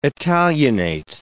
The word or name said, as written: italianate